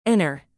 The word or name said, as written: inner